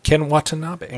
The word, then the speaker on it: Watanabe: Ken Watanabe.